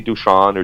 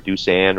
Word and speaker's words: Dušan: And the reason people call me Deuce, and whether they call me Dušan or Dušan or Deuce, it really doesn't matter. But Deuce came up short in my high school and college years, because it's spelled just as it sounds, Dusan.